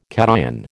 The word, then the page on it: cation: American English